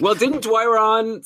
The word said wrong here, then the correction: Dorion: So there were mixed messages from Dorion.